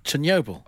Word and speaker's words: Chernobyl: Chernobyl.